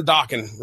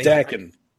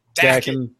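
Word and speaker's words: Daken: how do we pronounce that? Dakin or Dokken? – Dakin. Dakin. Because Dokken is a really crappy band.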